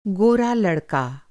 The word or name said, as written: गोरा